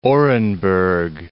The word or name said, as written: Orenburg